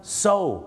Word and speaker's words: sew: so